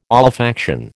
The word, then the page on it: olfaction: American English